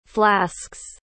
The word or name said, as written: flasks